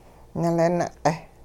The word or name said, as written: nilin-q'eh